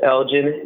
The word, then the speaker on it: Elgin: My full name is Elgin. That's E-L-G-I-N Bokhari, B-O-K-A-R-I, T for Thotmes, Smith. Thotmes is spelled T-H-O-T-M-E-S, and Smith is the most generic way of how you smell spelled Smith, is S-M-I-T-H.